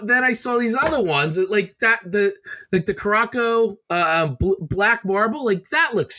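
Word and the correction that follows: Curaçao: Just to fill you in, that's pronounced Curacao.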